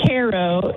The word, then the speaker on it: Cairo: Actually, it isn't from Cairo. It's pronounced Caro in this parts of the world, but darn it.